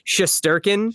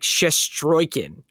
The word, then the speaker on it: Shesterkin: Is that how you pronounce it? – There's a couple of different pronunciations. I think the one that's just sort of like the easy American way of saying it is Shesterkin. – Shesterkin. – But I think Shesterkin. It's spelled the like American way that it was changed to is Shesterkin, I think. But the Russian spelling is more pronounced like Shestroykin or something like that.